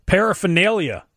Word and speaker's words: paraphernalia: Paraphernalia. That's another tough one. Instead of adding a letter like with orangutan, people pronouncing this already tricky word tend to skip over the R altogether.